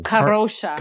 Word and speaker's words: Kadrolsha: Kadrolsha. – Kadrolsha. – You kind of you roll the L that goes after Kadrolsha. – The D. – Kadrolsha. – Like the D is silent.